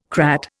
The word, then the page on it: crat: Indian